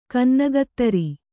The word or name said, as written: ಕನ್ನಗತ್ತರಿ